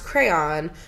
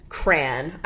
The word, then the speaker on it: crayon: How do you pronounce crayon with one syllable rhymes with man? Crayon. I honestly hate that. – Oh, my God. – They use Rose Art crayons, let's be real. – With two syllables sounds like crayon, which is me. Crayon. With two syllables where the second syllable rhymes with dawn, crayon.